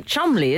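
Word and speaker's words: Cholmondeley: Like Chumley, isn't it? – Chumley? – Yeah, which is spelt Chomley.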